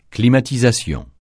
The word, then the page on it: climatisation: francais